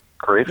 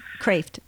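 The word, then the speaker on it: Kreeft: I hope I pronounced his last name correct. – Kraft. Yes, Kraft.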